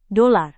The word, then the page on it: dolar: Indonesian